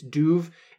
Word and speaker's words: Dubh: So Krondu, do is spelled D-U-B-H. It's Duv. It's actually the same sound that's at the beginning of Dublin.